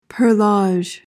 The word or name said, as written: perlage